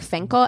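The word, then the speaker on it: Fencl: It's spelled Fency, but it's pronounced like Finkel. Everything I've said has looked like Finkel.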